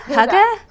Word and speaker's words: hygge: And hygge. – Yeah, what's it called? – Okay, I just looked it up to see how to say it again. Hygge? – Hygge? Hygge. It is hygge.